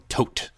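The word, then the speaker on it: Toht: Tot? How do you say it? – I'm just going to listen to you say that. – Tote, the Nazi dude. – Taint? No, it's Major Arnold Tote.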